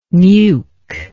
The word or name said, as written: neuk